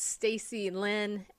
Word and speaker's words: Stacy Lynn: If you want to follow me on TikTok, it's Stacy Lynn, LOJ. So it's my name. Look here, it's spelled Stacy, L-Y-N-N, with no E anywhere.